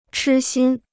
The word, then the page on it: 痴心: chī xīn